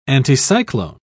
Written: anticyclone

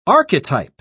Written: archetype

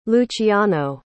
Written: luciano

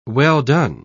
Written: well-done

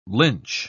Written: lynch

